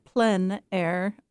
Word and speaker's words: plein air: Well, Plein Air, the word itself, comes from the French. So they pronounce it Plein Air, P-L-E-I-N, or you can also say Plein Air.